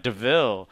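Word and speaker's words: De Vil: it's spelt like devil but pronounced like Deville. Like, and they're like, and then she's just like, yeah, like that's part of it.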